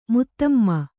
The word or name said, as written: ಮುತ್ತಮ್ಮ